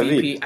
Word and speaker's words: Veep: And then on my radar, too, is VP. Or Veep. I don't know how. How do we say it? Veep? – Yeah, Veep.